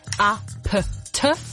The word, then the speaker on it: APT: Ausgesprochen wie, wenn wir einem Grundschüler erklären, wie Buchstaben klingen. A, P, T.